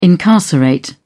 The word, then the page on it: incarcerate: British English